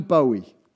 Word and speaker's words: Bowie: How do you say it? Bowie, Bowie. Bowie. We've got some Bowies. Depends if you're northern or southern, apparently.